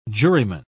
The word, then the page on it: juryman: British English